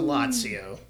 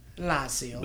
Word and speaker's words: Lazio: So they lost 2-1 to Lazio. And it was one of those games. – Say it right. Lazio.